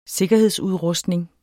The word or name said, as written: sikkerhedsudrustning